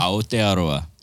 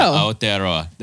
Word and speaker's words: Aotearoa: Aotearoa. – Yeah. – There you go. Aotearoa.